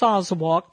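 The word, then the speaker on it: Azawakh: Get to know the latest breed recognized by the American Kennel Club, or AKC, the Ozawak. Pronounced Ozawak, this elegant beauty is an ancient breed that originated in West Africa.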